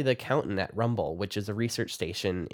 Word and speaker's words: RMBL: She's a stream ecologist at the Rocky Mountain Biological Laboratory in Gothic, pronounced RMBL or Rumble.